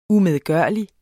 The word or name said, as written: umedgørlig